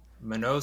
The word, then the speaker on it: Mano: I'll guide you through it. – Mano. – It's Mano. Mano. Sundaresan. – Mano Sundaresan. – Yes. Yeah, yeah.